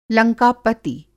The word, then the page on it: लंका-पति: Hindi